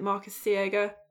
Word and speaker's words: Marcos Siega: It was directed by Marcus, I think you, you pronounce it Marcos Siega.